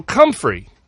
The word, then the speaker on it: comfrey: I'm not sure how you spell that offhand, but it's pronounced Comfrey. – Oh, Comfrey.